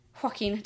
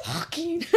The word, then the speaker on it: Joaquin: Joaquin. – Did I say it like that? – It kind of sounded like I kind of breathed right when I was saying that. Joaquin.